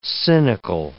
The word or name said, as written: cynical